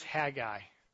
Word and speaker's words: Haggai: And I was told last week, you do the proper way to say it is Haggai.